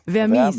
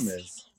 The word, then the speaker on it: Vermis: Vermis, ist das Englisch ausgesprochen oder ist es Vermis? – Ja, es ist lateinisch. – Vermis. – Vermis.